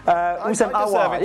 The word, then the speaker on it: Houssem Aouar: Who are you talking about? – Houssam. – I'm sorry. I shouldn't have. That was really mean. Houssam Aouar. – Yeah, Houssam Aouar.